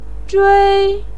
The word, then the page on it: zhui: 1